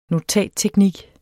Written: notatteknik

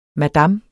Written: madam

